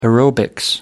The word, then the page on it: aerobics: American English